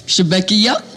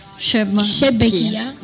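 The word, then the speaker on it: chebakia: Come si pronunciano? – Allora, shbekia. – Ah, come? – Sono difficile. – Provo a ripetere, vai. – Shebekia. – Meglio? Meglio, sì.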